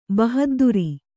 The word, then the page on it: ಬಹದ್ದುರಿ: bahadduri